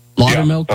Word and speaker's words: Loudermilk: And that was when your colleague, is it Congressman Loudermilk? Am I pronouncing that correctly? Loudermilk? – Yeah.